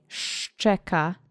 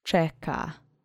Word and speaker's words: szczeka: Let's say szczeka together slowly three times, so you can really hit both of those phonemes in the start. Accent is on the first syllable. Szczeka.